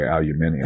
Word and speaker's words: aluminium: Oh, wow or as the British say, aluminium.